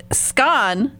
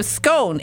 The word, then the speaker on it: scone: She said scone instead of scone. In America, we say scone. – And exactly. – For me, having lived in England a long time, of course I say scone. And with the British baking show, I think more and more Americans are pronouncing it that way.